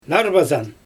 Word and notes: narbazan: Xyzyl

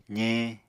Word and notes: zeñ: apatani

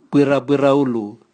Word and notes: bwirabwiraulu: Raga

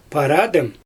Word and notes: paradIm: Xyzyl

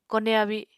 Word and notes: kone abi: Apatani

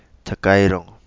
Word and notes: Takairongo: Kapingamarangi

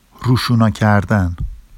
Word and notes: ruʃunɑ cardan: Vafsi

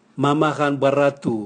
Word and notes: mama gan bwaratu: Raga

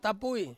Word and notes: tapui: Ansus

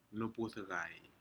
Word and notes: nupudcai: Aneityum

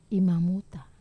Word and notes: mamuta: Wamesa

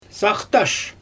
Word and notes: saxtash: Xyzyl

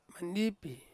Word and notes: mandipi: Ansus

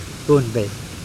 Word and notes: Pohnpei: Mokilese

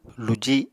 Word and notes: luji: Apatani

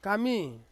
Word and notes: kami: Ansus

